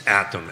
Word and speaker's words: Attom: It's Attom, ATTOM.